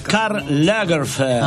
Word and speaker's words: Karl Lagerfeld: Claro, Karl Lagerfeld. – Ay, hermoso, Lagerfeld. Qué bien que lo pronuncia.